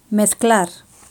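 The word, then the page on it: mezclar: Spanish (Spain)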